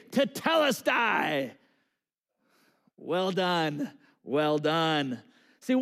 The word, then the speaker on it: Tetelestai: And it's pronounced this. Tetelestai. So I want you to repeat that after me. Just say it out loud. Tetelestai.